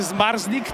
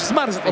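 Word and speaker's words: Zmarzlik: Proszę Państwa, to jest nazwisko niezwykle trudne, bo tutaj mamy 5 spółgłosek obok siebie. ZMRZL. Zmrzly. No, spróbujemy, prawda? – Jak wymawiamy zmarznik, to no niewiele się różni. – Niewiele, tak? Ale jednak brakuje tej jednej samogłoski w środku, więc proszę nam wybaczyć.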